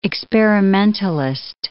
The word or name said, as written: experimentalist